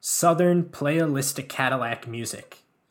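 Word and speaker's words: Southernplayalisticadillacmuzik: Southern Playalistic Cadillac Music.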